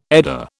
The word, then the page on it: Edda: British English